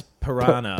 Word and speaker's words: piranha: I've heard you pronounce piranha. – Piranha. – Differently piranha. Every time. Piranha. – Yeah. – Piranha. – It's piranha. Piranha sounds a bit posh, don't you think? – That is how it's pronounced. – Is it how it's pronounced? I always thought it was like you had a choice. – No. It's not like dance and dance. – Yeah, okay. – No, it's a piranha at all times, I'm pretty sure.